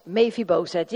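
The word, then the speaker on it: Mefiboset: Misschien kan je het anders uitspreken, maar ik zeg het op z'n Hollands. Mephibozet. Ja, we zijn een Nederlandse kerk, dus Mephibozet.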